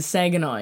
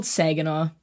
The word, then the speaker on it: Saginaw: Well, I mean, we're definitely going to bring up the fact that everyone torched you for pronouncing Saginaw incorrectly, but maybe we'll get to that later. – How is it pronounced? – It's called Saginaw.